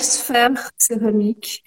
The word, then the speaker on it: Sphère Céramique: Euh, bah, je m'appelle Gaëlle Sphère Céramique. Euh, ça se prononce comme une sphère, euh, comme une sphère.